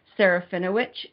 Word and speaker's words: Serafinowicz: Did I say that right? – Sarfinowicz. Yeah. – I was close. – I was just going to say that it ends like sandwich. – Okay, Serafinowicz. – Yes, that's my understanding.